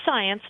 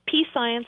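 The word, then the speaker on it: Psience: It's P-S-I-E-N-C-E. And it is pronounced science, not P-science or P-science. Although science. I've heard it pronounced those ways, too, and that's perfectly fine.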